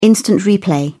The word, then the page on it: instant replay: British English